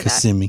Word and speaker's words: Kissimmee: How do you say Kissimmee.